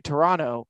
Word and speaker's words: Toronto: They don't pronounce the last T in Tor- Toronto. So when I was heading into Toronto, there was so many different advertisements for Blue Jays.